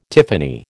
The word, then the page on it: tiffany: American English